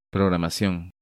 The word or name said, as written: Programacion